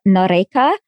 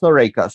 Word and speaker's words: Noreika: Okay, first one, can we talk a little bit about General Noreika? Is that his name? Am I pronouncing his name correctly? – Yes, yes, very correctly. Yes, Jonas Noreika.